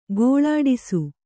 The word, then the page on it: ಘೋಳಿಡಿಸು: ghōḷiḍisu